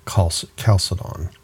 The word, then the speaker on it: Chalcedon: So we've read the definition of chalcedon, the CH is pronounced as a hard K, chalcedon.